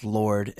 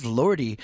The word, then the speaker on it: Lorde: her name is pronounced Lorde and the E is silent. I have everyone coming up to me going, what's up with this Lordy?